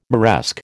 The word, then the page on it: Moresque: American English